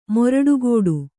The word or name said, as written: ಮೊರಡುಗೋಡು